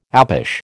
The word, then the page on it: apish: American English